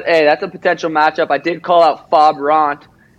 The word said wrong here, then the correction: Rob Font: His name's Rob Font, and people were like, you said his name wrong.